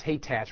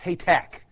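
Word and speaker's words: Hathach: And you think it's Haytach. Well, it's Haytack. It sounds like a K on the end. So anyway, if you hear the preacher saying Haytack and think that he's got a speech impediment, that's not it.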